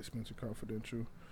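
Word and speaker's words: Spenser Confidential: Did I say that right? – Yeah, Spence Confidential.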